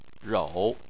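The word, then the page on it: rou: tone 3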